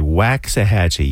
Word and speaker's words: Waxahachie: All right, so I'm always careful to pronounce this correctly. Waxahachie.